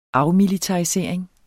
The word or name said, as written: afmilitarisering